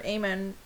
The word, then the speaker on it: Aemon: Jon begs Maester Aemon. Did I say that right? – Sure. – Maester Aemon to grab.